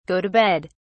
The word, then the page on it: go to bed: British English